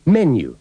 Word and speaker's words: menu: Notará que la palabra menú lleva el acento en la primera sílaba y no en la segunda. – Menu.